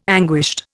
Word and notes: anguished: Indian

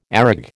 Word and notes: areg: American English

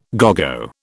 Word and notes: à gogo: British English

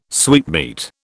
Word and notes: sweetmeat: British English

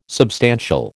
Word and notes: substantial: American English

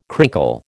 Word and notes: crinkle: American English

